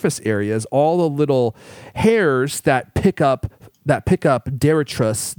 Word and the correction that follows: detritus: Did I say that right? Someone corrected me last time and I had to go look it up. I think I got detritus. – Detritus. Thank you.